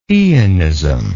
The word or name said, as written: eonist